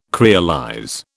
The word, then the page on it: creolize: British English